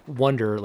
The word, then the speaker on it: Oneder: So when we're talking about Wonder Effects, and if you're on the Instagram, you'll see how it's spelled, but it's spelled one, like the number one, O-N-E-D-E-R.